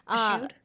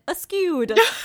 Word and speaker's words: eschewed: Eschewed? – Eschewed. I've literally always pronounced it askewed. – No, it's eschewed.